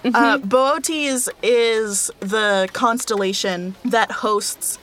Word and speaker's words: Boötes: Mm-hmm, it's spelled Boötes, and I want it to be booties. I want it to be booties so bad. – It should be booties. – I love booties. – It should be. – But it's not. It's Boötes.